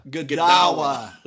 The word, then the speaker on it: Godawa: Godawa.